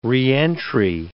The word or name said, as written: reentry